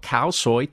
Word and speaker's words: khao soi: Is that spelled like cow soy? – No, it's spelled K H A O and then S O I.